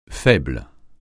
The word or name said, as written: faible